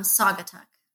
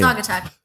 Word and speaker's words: Saugatuck: I never know how to pronounce Sagatuck. Is it Sagatuck? Sagatuck? – It's Saugatuck. – Okay, so I'm way Saugatuck. Yeah, I mean you were close, but yeah.